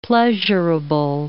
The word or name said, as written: pleasurable